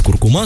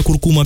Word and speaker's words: куркума: В русском языке изменилась норма ударения в слове «куркума». Теперь основным вариантом считается на последний слог.